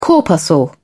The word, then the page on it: corpuscle: British English